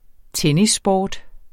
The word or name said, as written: tennissport